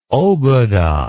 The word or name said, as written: obuda